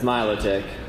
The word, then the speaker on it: Milotic: Yeah, because no one knows how to say its name right, but it's Milotic, unfortunately. And I don't say it. I don't like saying it, but that's how you say it.